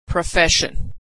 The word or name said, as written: profession